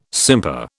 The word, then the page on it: simper: British English